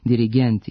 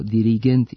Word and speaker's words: dirigent: Zbog toga se oblici sa kratkosilaznim akcentima na poslednjem slogu, dirigent i asistent, akcentuju kao dirigent i asistent sa kratkouzlaznim akcentima na pretposlednjem slogu.